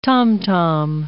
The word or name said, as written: tom-tom